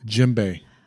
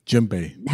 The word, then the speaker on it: djembe: Djembe. Djembe. – How do you spell it? – D-J-E-M-B-E. – Yeah. – Djembe. – It's very cool looking. – Not djembe, but djembe.